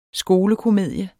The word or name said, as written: skolekomedie